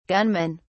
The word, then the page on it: gunmen: British English